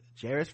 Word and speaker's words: Jerris: jerris Rhymes with Paris. And this is from Hong Kong.